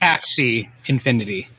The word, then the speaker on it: Axie Infinity: Axis Infinity. Axis, am I saying that wrong? Probably right. – Axie Infinity, thank you.